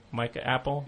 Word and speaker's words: Appel: And along those lines, Tom, all about Mike Appel, is that how you pronounce his name? Mike Apple? – Yeah. – Mike Appel.